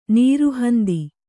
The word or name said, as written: ನಿರಹಂತೆ